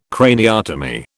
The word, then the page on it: craniotomy: British English